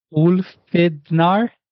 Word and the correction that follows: Ulfhethnar: Close, close. You got Ulv, OOLV, Ulv. Heth is HETH, and then NAR. And Ulfhethnar, those were warriors, according to Norse legend.